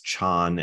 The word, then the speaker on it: chan: The original Indian word for meditation is dhyana, and the Chinese pronounce that as chan, and then the Japanese pronounced it as zen.